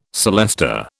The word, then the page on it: celesta: British English